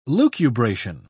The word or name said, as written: lucubration